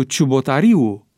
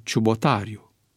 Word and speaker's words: Ciubotariu: Astfel se pronunță Tudor Chiuariu în loc de Tudor Chiuariu, Răzvan Cojocariu în loc de Răzvan Cojocariu, Liviu Ciubotariu în loc de Liviu Ciubotariu, Liviu Olariu în loc de Liviu Olariu, Dumitru Prunariu în loc de Dumitru Prunariu, Mihai Trăistariu în loc de Mihai Trăistariu.